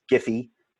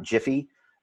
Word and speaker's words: Giphy: You know, it's Giphy It's not Jiffy, but Jif, creamy all day long.